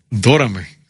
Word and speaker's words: Dórame: Dórame, sí, sí. Con acento en la O.